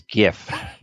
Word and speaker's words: GIF: It is GIF, the GIF party. – Nice. – I just want to make sure that you were on the correct end of the spectrum and not going to the soft G, because we here at Bird's Eye View do not approve of the soft G.